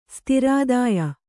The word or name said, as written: ಸ್ತಿರಾದಾಯ